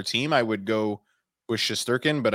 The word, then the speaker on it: Shesterkin: Shesterkin, is that how you pronounce it? – Yeah.